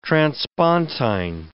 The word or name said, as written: transpontine